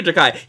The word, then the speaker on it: Takei: is it Takei or Takai? He says Takei. George Takei actually says Takei.